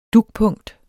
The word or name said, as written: dugpunkt